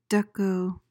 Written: dekko

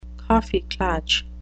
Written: kaffeeklatsch

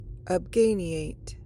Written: obganiate

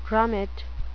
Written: grommet

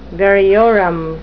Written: variorum

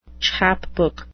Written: chapbook

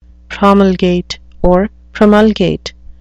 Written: promulgate

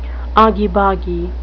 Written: argy-bargy

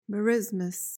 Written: merismus

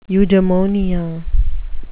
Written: eudemonia

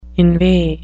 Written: inveigh

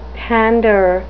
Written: pander

